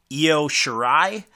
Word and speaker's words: Io Shirai: The matchup is between Toni Storm and, I know I'm going to say this wrong, Io Shirai.